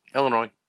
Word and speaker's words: Illinois: Illinois. The S is silent. – Uh-huh. Right. – Yeah, the S is silent. – I do hear people say Illinois. – Yes, but if you hear anyone that's from Illinois say Illinois, they say Illinois.